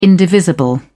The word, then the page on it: indivisible: British English